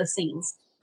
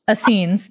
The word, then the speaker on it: Essenes: If I pronounce it right, the Essenes? – Oh, I know. I've heard of that before. Okay. – Yeshua just corrected me. He's like, they were the Essenes. – Essenes, yeah.